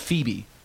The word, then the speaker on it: Feabie: It's called Phoebe. – Feed me? – Phoebe. – Phoebe. – Yeah, Phoebe. – How do you spell it? – F-E-A-B-I-E. – F-E-A-B-I-E? – Yeah.